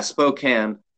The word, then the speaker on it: Spokane: Yeah, Spokane.